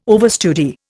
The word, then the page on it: overstudy: Indian